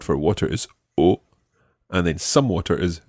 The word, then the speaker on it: eau: The word for water in French is eau. – Eau. – It's spelled E-A-U, eau.